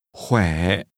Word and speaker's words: khỏe: Khỏe. – Did you pronounce the falling, rising tone as the speaker did? – Khỏe.